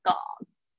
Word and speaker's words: gauze: Gauze，这个 A U 呢是发那个短音的啊，Gauze，Gauze，英式发音可能会念成 Gauze，但是很些微差别。